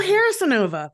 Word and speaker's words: Harazinova: Yeah, it's Harazinova, like hair. – Harazinova. – Yeah. – Oh, Harazinova. That's easy to remember. I've always been saying Harazinova, which says harass.